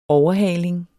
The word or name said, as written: overhaling